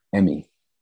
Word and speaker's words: Emmy: Did you say her name was, is it Amy or Emmy? – Emmy.